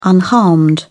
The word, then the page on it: unharmed: British English